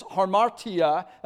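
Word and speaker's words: hamartia: Sins there is harmatia. That's how you say it in the Greek.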